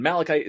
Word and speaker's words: Malikyte: It's spelled E-R-I-C for Eric. And then Malachite is spelled M-A-L-I-K-Y-T-E.